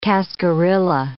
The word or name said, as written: cascarilla